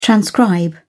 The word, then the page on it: transcribe: British English